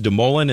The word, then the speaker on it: DeMoulin: That is pronounced De Mullen and spelled D-E-M-O-U-L-I-N.